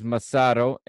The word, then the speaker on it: Massaro: Massaro, I was waiting for the pronunciation from the announcer. One of those names I sometimes struggle a little bit to pronounce. And it is Massaro as he comes on for Ancelotti.